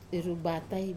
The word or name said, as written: rubatai